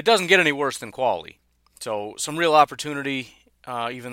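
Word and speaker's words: Qvale: And then right tackle, Mr. Brent Qualley is how you say his name. It's Q-V-A-L-E. I don't know how you expect a guy whose name is Q-Vale, pronounced Qualley, to play football well or do anything well, because that's just messed up.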